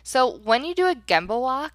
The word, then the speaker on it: Gemba: So first things first, Gemba. That's spelled G-E-M as in Mary, B as in boy, A, Gemba.